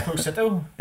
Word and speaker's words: Esposito: Esposito.